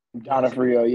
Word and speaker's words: Donofrio: Donofrio, yeah.